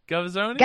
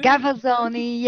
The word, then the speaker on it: Gavazzoni: Gavazzoni? Gavazzoni, yes, yeah, you're right, Joe.